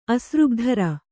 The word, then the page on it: ಅಸೃಗ್ಧರೆ: asřgdhare